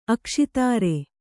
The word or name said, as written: ಅಕ್ಷಿತಾರೆ